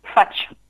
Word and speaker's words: fatch: Et en occitan, fatch. Uh-huh. Voilà, qu'on prononce avec le T avant.